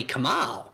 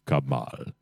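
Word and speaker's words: Kamahl: But they were very excited because they're pushing the yes vote, and then apparently Kamal, once again, you mispronounced it, Greeno. It's pronounced Kamal.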